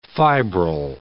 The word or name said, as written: fibril